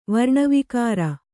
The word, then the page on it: ವರ್ಣವಿಕಾರ: varṇa vikāra